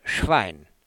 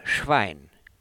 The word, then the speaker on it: Schwein: But the two vowel letters in the middle of Schwein are a double sound, I, I, Schwein, Schwein. Knie und Schwein, Knie und Schwein, Knie und Schwein.